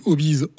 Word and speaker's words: Obiz: Et à un moment donné, j'ai pris la décision de créer Hobbies, donc qui s'écrit Hobbies, O-B-I-Z, mais qui fait référence au Hobbies, H-O-B-U-S.